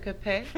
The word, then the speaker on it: épée: You're going to have to help me with the pronunciation once I get to the point of revealing this. – What weapon? – Yeah, what weapon, it says. – The non-electric épée? – You pronounced it right.